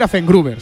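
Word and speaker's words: Affengruber: Me ha gustado mucho cómo lo has pronunciado en la prese. – ¡Afengruba! – ¡Afengruba! Oye, voy a decir Afengruber.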